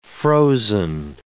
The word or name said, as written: frozen